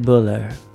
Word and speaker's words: Buller: Ed Buller. – It's spelled Bueller. – Is it Bueller with an E? – Well, no. It's B-U-L-L-E-R. – All right, so I'm going to go Buller.